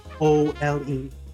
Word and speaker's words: OLED: But if you are a new Nintendo Switch, you know, you want that console, then go for the OLED. – Just pronounce it. OLED. That's OLED. don't make it hard for yourself.